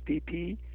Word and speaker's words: Phi Phi: and you go down to Phuket, um, they offer uh, day trips uh, down to the uh, Koh Phi Phi Islands is P H I P H I, but pronounced P P.